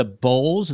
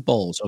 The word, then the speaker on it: Bolles: She attended the Bowles. Is that correct? B-O-L-L-E-S. Is that right, Shannon? Mm-hmm. Bowles. Okay. I just wanted to make sure I pronounced it right.